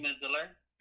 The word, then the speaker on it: Midler: Sarah Jessica Parker, Betta Milder, or Milder, I'm not sure how you pronounce it. And also Kate Midler.